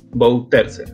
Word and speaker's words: Bouterse: Bouterse. Eh, ¿Lo dije bien? Bouterse, sí.